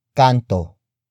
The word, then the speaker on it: kanto: and that is kanto. Kanto. Kanto. Kanto is spelled as K-A-N-T-O.